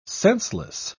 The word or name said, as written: senseless